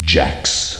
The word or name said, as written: jax